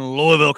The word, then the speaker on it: Louisville: All right, we're in Louisville, Kentucky you gotta say it right. It's not Louis. It's not Louis. It's Louisville.